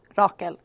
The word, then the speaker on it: Rachel: I've been Rachel almost my entire life, but in Swedish, it's actually pronounced Rakel.